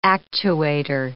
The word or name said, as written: actuator